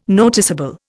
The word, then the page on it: noticeable: Indian